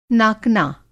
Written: नाकना

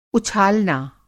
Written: उछालना

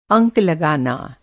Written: अंक

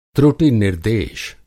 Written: त्रुटि-निर्देश

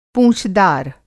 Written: पूँछदार

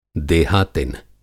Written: देहातिन

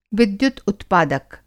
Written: विद्युत-उत्पादक

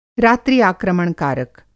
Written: रात्रि आक्रमण कारक

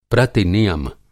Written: प्रतिनियम

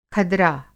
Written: खदरा

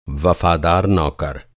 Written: वफ़ादार

